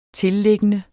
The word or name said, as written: tilliggende